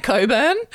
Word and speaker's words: Cockburn: It's like Coburn, but it's spelt Cockburn.